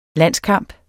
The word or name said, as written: landskamp